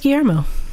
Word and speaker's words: Guillermo: Knock, that's Guillermo.